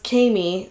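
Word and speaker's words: Kaymie: No, it's Kami. It's K-A-Y-M-I-E. Okay. Kami. That's how I pronounce it.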